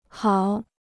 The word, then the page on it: 好: hào